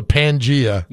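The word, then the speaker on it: Pangaea: Right in the middle of the Pangea. – No. – Is that it? Did I say it correctly? – You did, yeah.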